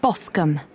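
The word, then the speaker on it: Boscombe: Joy Boscom. – How do you spell that? – J-O-Y-B-O-S-C-O-M-B-E.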